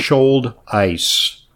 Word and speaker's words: Schulteis: It's the Schulteis Clinic, like spelled like shoulder, but the first without the ER, with an ice at the end, mm-hmm. like Schulteis, okay? – So, uh-huh.